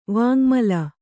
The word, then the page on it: ವಾಙ್ಮಲ: vāŋmala